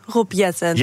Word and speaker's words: Jetten: Nou, de uitspraak was wel goed, Rob Jetten.